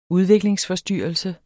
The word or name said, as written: udviklingsforstyrrelse